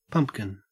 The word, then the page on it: pumpkin: Australian English